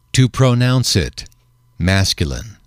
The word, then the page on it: to pronounce it: male